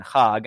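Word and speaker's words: Chag (חג): And Chag, I'm using a little phlegm here because that's how you have to pronounce it in correct Hebrew.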